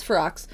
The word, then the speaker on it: Ferox: Because I always thought it was Furrow, too, and then I heard it, and I was like, oh, it is Ferox.